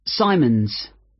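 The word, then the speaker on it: Symonds: It's Simons. – Is that like Simon with an S? – No, it's S-Y-M-O-N-D-S. Most people find it rather difficult to spell. – I see. It has a silent D.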